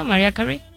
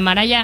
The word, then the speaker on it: Mariah Carey: ¿Mariah Carey? – Mariah Carey. – A Mariah Carey. – Ajá. – A la Maraya, a la Mariah Carey.